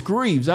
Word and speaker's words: Greaves: Did I pronounce that right? Is it Graves? Is it Graves or Greaves? Oh, it's Greaves.